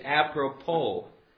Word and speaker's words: apropos: I want you to say that top line with me, but before we do, notice the pronunciation of that last word. It is apropos. Apropos. I don't know what language that comes from with the S on the end, but apropos means to the point, pertinent, and at an opportune time.